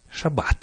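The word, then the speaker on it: Шаббат (שבת): Шаббат.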